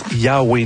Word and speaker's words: YHWH: Ça s'appelle YHWH Nail Gun. Ça se prononce Yahweh Nail Gun.